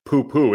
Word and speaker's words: pupu: I mean, it's unfortunate that it happens to be poo-poo. It's spelled P-U-P-U as opposed to P-O-O-P-O-O.